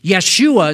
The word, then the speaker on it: Yeshua: Yeshua is the way you pronounce it in Hebrew, which is Yahweh saves, the Lord saves.